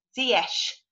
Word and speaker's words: Ziyech: So I kindly asked him if he'd kindly voice note me with the correct pronunciation, and it's Ziyech.